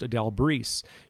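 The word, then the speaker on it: Adele Brise: And she appeared only three times to Sister Adele Bryce, also sometimes pronounced Adele Brees.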